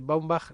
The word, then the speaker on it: Baumbach: Baumbach, como se, totalmente como se pronuncia, ¿no?